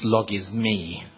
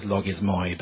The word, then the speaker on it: logismoi: That word is pronounced logismi. I know it says logismoi, but that's in modern Greek we pronounce that as logismi.